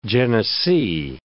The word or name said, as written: Genesee